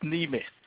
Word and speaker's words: Nemeth: That's N-E-M-E-T-H. Maria. Did I say it right? Nemeth. Yes, Nemeth.